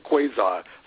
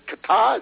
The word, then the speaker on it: Qatar: Qatar, I guess that rhymes with guitar, and Turkey, I think, are the only countries that are really speaking out about what's going on.